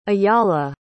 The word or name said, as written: ajala